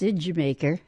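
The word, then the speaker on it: D'yer Mak'er: K-O-T, the song spelled Dyer Maker, but pronounced Idjamaker.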